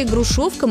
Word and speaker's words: грушовка: Есть такой старинный русский сорт народной селекции – грушовка московская. Ударение на втором слоге. Пишется через «о».